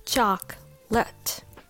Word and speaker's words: chocolate: Well, first of all, in English, this word is often pronounced with only two syllables chocolate.